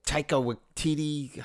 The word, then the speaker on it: Taika Waititi: We wanted the Taika Waititi. How do you say his name? – You got it. Nailed it.